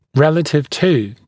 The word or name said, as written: relative to